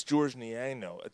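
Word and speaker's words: Georges: Yeah, but it's George Niano. The S is silent.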